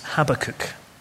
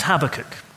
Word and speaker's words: Habakkuk: His name is Habakkuk, although others pronounce it differently. His name is Habakkuk.